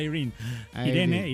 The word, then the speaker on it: Irene: Pero ¿sabes que Irene, o Irene, como le dicen los americanos? – Tontos. – Se pronuncia Irene.